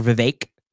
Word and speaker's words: Vivek: Vivek Ramaswamy, or Vivek, like cake, they say that's how you pronounce his name.